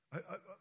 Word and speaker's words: Yahweh: You pronounce it correctly by breathing in and then breathing out. Yahweh.